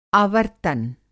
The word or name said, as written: आवर्त्तन